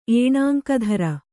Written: ಏಣಾಂಕಧರ

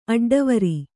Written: ಅಡ್ಡವರಿ

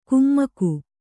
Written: ಕುಮ್ಮಕು

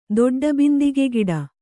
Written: ದೊಡ್ಡಬಿಂದಿಗೆಗಿಡ

